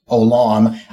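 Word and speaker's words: Olam (עולם): I keep wanting to say Olam or something like that, but or again, I see it more mostly as Olam El, and Olam means everlasting, and that's just pronounced Olam.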